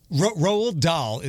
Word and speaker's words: Roald Dahl: Roald Dahl. Am I saying it right? – Yeah.